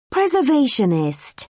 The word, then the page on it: preservationist: British English